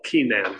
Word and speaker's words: Kenan: Most ones are pronounce it or spell it Kenan, so people don't get confused with Canaan.